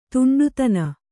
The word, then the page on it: ದಿಂಡುತನ: Kannada